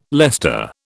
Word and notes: Leicester: British English

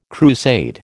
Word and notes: crusade: American English

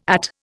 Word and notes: At: Indian

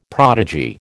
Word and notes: prodigy: American English

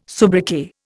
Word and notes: soubriquet: Indian

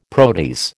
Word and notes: protease: American English

